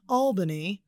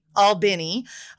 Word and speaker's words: Albany: And I'm adopted, but I'm pretty sure I was born in Albany, not Albany. Albany, Georgia is how we pronounce it.